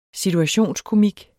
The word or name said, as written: situationskomik